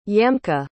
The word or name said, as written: yamka